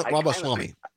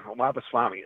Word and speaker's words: Ramaswamy: I can't say his name, Swami, Viva Ramaswamy. – Ramaswamy.